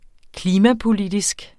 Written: klimapolitisk

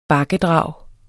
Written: bakkedrag

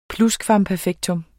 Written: PLUSKVAMPERFEKTER

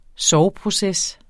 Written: sorgproces

